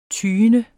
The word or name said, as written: TYENDERS